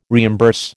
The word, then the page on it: reimburse: American English